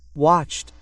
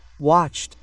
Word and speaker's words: watched: Watched. Watched. Like a T pronunciation at the end.